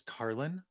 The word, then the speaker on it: Carlin: So it's Charles Carlin, just like the comedian.